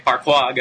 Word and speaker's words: Parkour: I believe it's called Parkour in French, or it's spelled P-A-R-K-O-U-R. And I actually asked my boss, who is French, how you pronounce it. And he said it's like Parkour. I have no idea if I'm really pronouncing it right, but you know, Parkour, something like that.